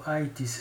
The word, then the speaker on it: Aythis: Intanto dice il gruppo ITIS, si scrive A-Y-T-H-I-S.